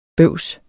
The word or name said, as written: bøvs